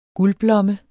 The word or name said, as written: guldblomme